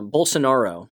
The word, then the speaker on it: Bolsonaro: Bolas, uh, uh, Bolsonaro, my apologies for getting his name wrong, Bolsonaro was pushed into a runoff.